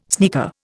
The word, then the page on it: sneaker: Indian